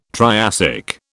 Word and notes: Triassic: British English

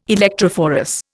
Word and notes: electrophorus: Indian

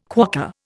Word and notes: quokka: Indian

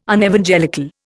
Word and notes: Unevangelical: Indian

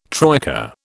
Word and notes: troika: British English

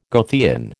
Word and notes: Goethean: American English

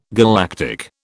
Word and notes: galactic: British English

